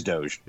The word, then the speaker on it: Doge: There's a part of me that kind of want to get into the Doge, whatever, however it's pronounced, Dogecoin. – Yeah, it's Doge.